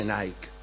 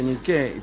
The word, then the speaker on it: Nike: Y no se dice Nike. Se dice Niqué.